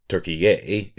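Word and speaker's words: Türkiye: Turkiye is how the country's name is pronounced in Turkish.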